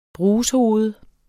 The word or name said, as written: brushoved